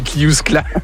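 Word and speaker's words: Cliousclat: C'est Kliouskla ? – Exactement, c'est Kliouskla.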